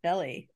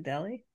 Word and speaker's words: Delhi: How do you pronounce? Is it Dali, India? Delhi, India? Dahlia? Dilly? Dehli? Quote, I was born in India. – Delhi. Isn't it Delhi? – Delhi, I think so.